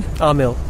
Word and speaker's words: Amil: And we were chatting with Amil. I said that right? – Amil. – Accent on the first syllable.